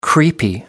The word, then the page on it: creepy: American English